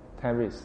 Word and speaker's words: Claris: I'm Clarice. – Clarice. Yeah. How do you spell that? – C-L-A-R-I-S.